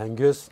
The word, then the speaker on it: Angus: Der Angus, normalement, qui ne doit pas être bien loin. Le petit garnement. Ouais. T'as vu, je l'ai bien prononcé. Hein, c'est ça.